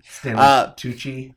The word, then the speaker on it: Stanley Tucci: Stanley Touchy. – Stanley Tucci.